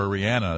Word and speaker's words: Rihanna: or Rihanna. That's how Kevin tells us to pronounce it.